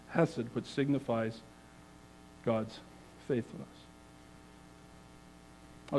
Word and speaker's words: hesed: And it's the word that we know as hesed, phonetically spelled H-E-S-E-D, hesed.